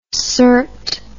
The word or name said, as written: cert